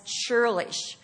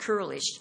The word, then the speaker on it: churlish: and some pronounce it curlish. This is the only time that word is mentioned in the Bible, just one time, and it refers to this man. Curlish or churlish, whichever you want to call it, and I saw it in my dictionary, can be pronounced either way, but we're used to saying churlish, means cruel, hard-hearted, and evil in his doings.